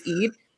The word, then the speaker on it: Eid: you can bully me if I mispronounce this. It's Eid. – Yeah, you got it.